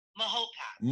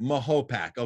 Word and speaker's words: Mahopac: Mahopac. – Mahopac, okay.